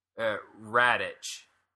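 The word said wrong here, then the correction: Rakitić: Rakitic. – Rakitic, maybe. Sure.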